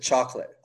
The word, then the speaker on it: Txokolat: So it's spelt in the Basque way of spelling chocolate, Spanish Basque. – And then you can, but you still pronounce it chocolate.